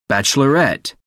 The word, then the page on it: bachelorette: American English